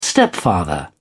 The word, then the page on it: stepfather: British English